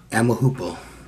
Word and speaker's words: amahoopo: A-V-R-I-C-E, Avarice. – Yeah. Avarice. – Yeah. – And how do you say goodnight? – You can say amahoop. – You can say amahoop? – Amahoopo. – Amahoopo? – Yeah, amahoopo, yeah.